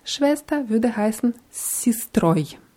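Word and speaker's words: Sistroi (с сестрой): Sistra, Sistroi. Padruga. – Spadrugoi. – Spadrugai. Ja, wenn die Endung oi betont ist, wie bei sistroi, wird, wird es auch deutlich wie o ausgesprochen.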